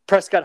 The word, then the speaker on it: Prescott: Prescott Hideaway. If you're local, you pronounce it Prescott, but that's annoying.